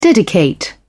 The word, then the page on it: dedicate: British English